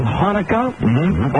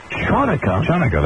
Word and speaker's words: Chanukah: Tonight is also the first night of Hanukkah, or as you Jewish people call it, Chanukah, that is the correct pronunciation.